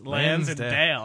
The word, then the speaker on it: Lansdale: Is that Lansdale? You're from Pennsylvania. I don't know. – It doesn't look that hard to pronounce. It's Lansdale.